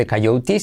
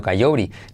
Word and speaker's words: coyote: La T es una letra semiclave de nuestro método que cuando va entre, entre vocales se convierte en R suave. No se, dice coyote, sino coyote.